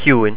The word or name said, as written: ciúin